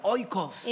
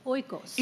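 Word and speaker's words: oikos (οἶκος): No, because the word home in the original language se pronuncia oikos. – Is pronounced oikos.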